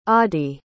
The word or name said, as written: adie